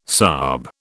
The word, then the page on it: sahib: British English